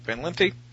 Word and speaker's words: Van Lente: I'd definitely go for Van Lente. Is that how you pronounce his name? Van Lente? – Yeah.